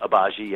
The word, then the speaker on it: Agbaji: Yeah, Ochai Agbaji. G-silent, Agbaji, yep.